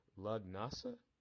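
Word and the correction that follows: Lughnasa: No, Dancing at Lunasa.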